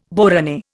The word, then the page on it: borane: Indian